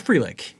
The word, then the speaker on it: Frelick: It's Frelick.